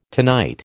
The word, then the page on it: tonight: American English